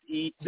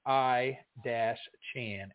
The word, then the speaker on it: Etienne: E-T-N. – This is what the back of the card says. It says his name. He has his name pronounced wrong every Saturday, E-T-N, when in reality it's pronounced I-Chan, A-Y-Chan. Interesting nugget because everybody on the in college football would always say E-T-N, and not Etienne, not it, you know, anything else.